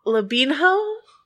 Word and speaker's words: Lobenho: Lobenho? – Yeah. I don't want to put the emphasis on the ho.